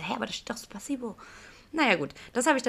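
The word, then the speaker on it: Spasiba (Спасибо): Ja, ich muss sagen, da habe ich auch ziemlich lange gebraucht, weil sie hat mir Spasibo aufgeschrieben, wird aber Spasiba ausgesprochen.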